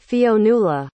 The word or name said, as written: Fionnula